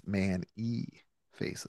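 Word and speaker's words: Man-E-Faces: It's Man E Faces.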